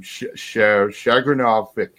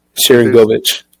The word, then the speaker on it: Sharangovich: Oh, I'm gonna butcher this name. Shagrinovic. – Sharangovich.